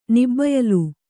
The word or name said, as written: ನಿಬ್ಬಯಲು